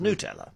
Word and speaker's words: Nutella: Lots of my listeners are saying it's made from nuts, so it must be Nutella. But we have established that the official pronunciation is Nutella.